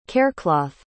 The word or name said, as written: carecloth